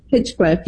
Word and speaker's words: Hinchcliffe: Is it Hinchcliffe? Is that how he pronounces it? – Hinchcliffe, yeah.